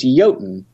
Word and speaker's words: Jotun: If anyone has a question about how to pronounce the game, because it's like from Nordic mythology and all that kind of stuff, it's Jotun.